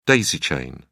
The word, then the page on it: daisy-chain: British English